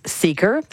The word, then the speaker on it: Seekr: That's Seeker. That's spelled S-E-E-K-R.